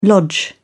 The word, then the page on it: lodge: British English